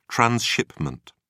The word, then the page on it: transshipment: British English